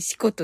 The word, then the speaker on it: 四股: 四またと書いて、四股と読みますが。